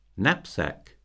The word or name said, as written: knapsack